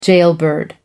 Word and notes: jailbird: American English

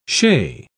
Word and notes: chez: British English

